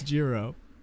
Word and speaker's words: Jiro: How do you pronounce Jiro? It's a soft J.